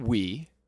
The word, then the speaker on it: Wii: Because it's called the Wii, and it's spelled W-I-I, okay. – But it's pronounced Wii.